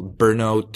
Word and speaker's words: Brno: Brno? How's it pronounced? – Brno. No, it's Brno. It's actually just a four-letter city.